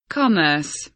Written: commerce

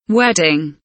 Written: wedding

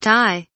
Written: tie

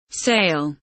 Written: sale

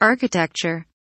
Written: architecture

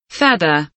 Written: feather

